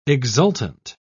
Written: exultant

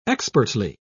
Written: expertly

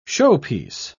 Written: showpiece

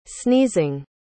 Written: sneezing

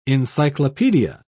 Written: encyclopedia

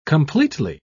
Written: completely

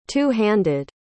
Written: two-handed

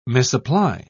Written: misapply

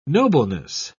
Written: nobleness

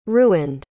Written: ruined

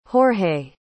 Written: jorge

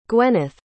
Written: gwenneth

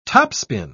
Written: topspin

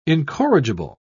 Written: incorrigible

